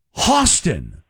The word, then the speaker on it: Hostin: Hey, Joey, and then someone called in and said, it's pronounced Hostin, or like Hawthorne, Hostin.